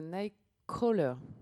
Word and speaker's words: Nightcrawler: Euh, Nightcrawler. – Voilà, tu le prononces mieux que moi.